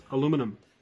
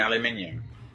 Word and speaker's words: aluminium: And, and how to, Also, you should also blog how to pronounce aluminum. – Oh, you mean aluminium? – No. We don't know what aluminium is. – It's aluminium.